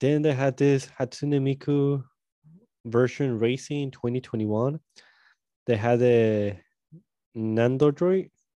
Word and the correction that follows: Nendoroid: No, Nendoroids. Nendoroid. There you go.